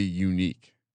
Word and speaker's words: Yannick: Yannick Ngakoue. – Did you know it's actually unique? – Unique? – Yeah. Okay. Shout out to our friends. – Y-A-N-N-I-C-K.